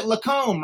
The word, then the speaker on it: LaCombe: So we pronounce it LaCombe, right? – That's correct.